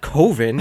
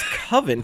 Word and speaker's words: Coven: He's the guy who says it's not Coven, it's Coven. It's pronounced Coven, unless you put an umlaut over it.